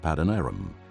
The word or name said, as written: Paddan-Aram